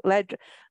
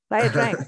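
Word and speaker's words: Ia Drang: Oh, sorry, I can't pronounce it. See, say that again. La Drang. La Drang. – La Drang. – La Drang. Okay, sorry.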